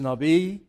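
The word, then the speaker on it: Navi (נביא): Well, the word prophet in Hebrew is Navi. It's V or B, depending on who you listen to or how you pronounce it.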